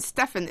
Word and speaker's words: Stephen: The PH in Stefan is pronounced with the letter V.